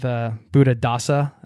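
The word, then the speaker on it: Buddhadasa: Buddha Dasa, am I saying that correctly? – That's it. Yes.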